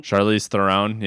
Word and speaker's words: Charlize Theron: My number three is, I'm going to butcher her name because I can't pronounce it, but it's Charlize Theron. – Charlize Theron, yes.